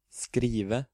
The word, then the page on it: skrive: Norwegian